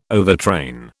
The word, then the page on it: overtrain: British English